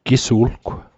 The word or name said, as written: Gisu'lgw